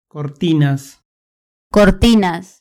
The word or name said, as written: cortinas